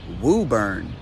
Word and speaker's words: Woburn: Okay, no silent letters in this one. In fact, it might even have an invisible letter, because this is pronounced Woburn.